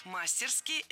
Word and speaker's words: мастерски: Мастерски – это теперь тоже норма. Хотя раньше следовало ставить ударение исключительно на последний слог – мастерски. Теперь это страшное наречие можно произносить по-разному. Мастерски и мастерски – двойная норма.